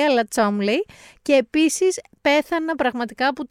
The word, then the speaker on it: Cholmondeley: Γράφεται όντως, παιδιά, Cholmondeley και προφέρεται Chumley.